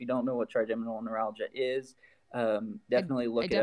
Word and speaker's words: neuralgia: But they wanted to ask if you guys can help with trimeningeal neuralgia. Am I saying that right? I know that I'm pronouncing that. – Neuralgia. – Yeah, neuralgia. Okay, there we go.